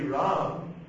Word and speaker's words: Ram: In Hindi, this is a one syllable word. Ram Mandir. Ram. A one syllable word. In Sanskrit, two syllables. Ram, Ram, Ram, Eti. – Ram, Ram, Ram, Eti. – Another verse, Ram, Ram, Ram, Eti. Satranam, Satyam, Ram, Ram, Ram, Eti. – So it's a two-syllable word.